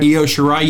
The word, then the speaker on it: Io Shirai: Leo Shirai, did I say it right? – Io Shirai, yes.